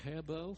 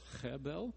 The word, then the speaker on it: hebel: In Hebrew, it's pronounced something like hebel, hebel. You and I would spell it H-E-B-E-L.